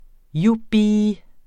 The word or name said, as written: jubi